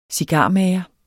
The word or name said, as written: cigarmager